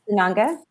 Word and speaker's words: Sananga: Is it Saranga? Saranga, the eye, what you put in the eyes? – Sananga. – Sananga, thank you.